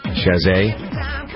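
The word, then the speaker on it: Chasez: Lance Bass and the NSYNC bandmates, J.C., is it Chazay? How do you pronounce that? – Chazay.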